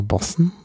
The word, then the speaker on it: Boatswain: Nämlich da hörte man eine Bootsmannspfeife oder Bootsmannpfeife oder auf Englisch Bossens Call, genau, Bossen. Weiß man gar nicht, wie man das schreibt. Schreibt man Boatswain, mit Bossen ausgesprochen.